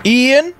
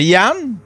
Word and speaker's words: Ian: Ian, c'est Yann, mais il écrit I-A-N. Comme en anglais, ceux qui le prononcent en anglais. Le monde qui prononce en anglais, c'est tellement Ian! Ian! Come here, Ian!